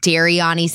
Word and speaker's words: Dariani: On Instagram at Dariani Santana. So it's spelled like Daria, New York, but my name is not Daria. I need everyone to know that. And then Twitter too. Just type in Dariani or just go on Jiraz and like he just look at who he follows.